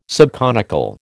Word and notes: subconical: American English

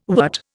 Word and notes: wort: Indian

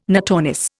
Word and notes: notornis: Indian

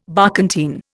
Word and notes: barkentine: Indian

